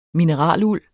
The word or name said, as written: mineraluld